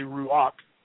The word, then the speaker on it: Ruach: Ruah, it means spirit. And it's actually Ruach. It's a CH sound at the end of that, even though there's no C in the word. It's Ruach, Ruach HaKodesh.